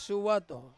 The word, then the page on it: suwato: Ansus